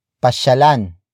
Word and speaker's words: pasyalan: For example, pasyalan natin ang liwasan. Pasyalan natin ang liwasan. Pasyalan natin ang liwasan. It means, let's go to the park. The focus is on the park. Word for word it's pasyalan natin ang liwasan.